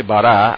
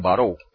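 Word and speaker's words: bará (ברא): Fíjense, la palabra creó, dice en hebreo, dice bará, aunque algunos lo pronuncian baró. El autor de que les mencioné al principio, Mordejai Ederi, la pronuncia baró.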